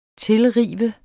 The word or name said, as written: tilrive